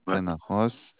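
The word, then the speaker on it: Penn Ar Roz: Penarros. Ça s'écrit des fois détaché aussi, hein. – Oui, je vais regarder. Ah oui. – PE2N détaché AR détaché ROZ. – C'est un Z, oui, c'était hop, Penaros.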